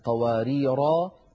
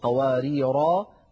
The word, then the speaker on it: قواريرا: والكلمة السابعة والأخيرة قوله تعالى وأكواب كانت قواريرا في سورة الإنسان كما أسلفت منذ قليل، فهذه الألف قواريرا تثبت عند الوقف، أما إذا وصلنا فإن تلك الألف تسقط ويكون النطق براء مفتوحة لا غير.